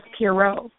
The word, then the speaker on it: Pierott: No, you're not, but that's okay. Everyone messes it up. – The proper pronunciation is Pierrot.